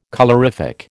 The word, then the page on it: colorific: American English